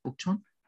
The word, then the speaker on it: Bukchon: And the Bukchon, is that how you say it? Bukchon? – Yeah, Bukchon, Bukchon Hana Village.